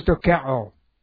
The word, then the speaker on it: dokeo: The Greek is dokeo. The word dokeo, if you're interested, the word dokeo looks like this, and D-O-K-E long O.